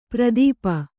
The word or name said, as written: ಪರಿತಾಪ